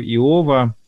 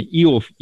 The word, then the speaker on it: Иов: И Петр впечатлился, перечитывая книгу Иова. А те, кстати, как удобнее. Вот я знаю два ударения. Иов, Иов.